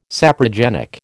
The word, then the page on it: saprogenic: American English